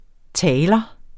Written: taler

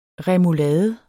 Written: remulade

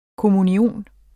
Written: kommunion

